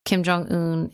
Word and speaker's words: Kim Jong-un: Kim Jong-un is. Is that his? That's the right way to pronounce his name.